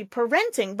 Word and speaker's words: parenting: So I think you said parenting. They're busy parenting, but it should be parenting. Syllable stress is on the same one.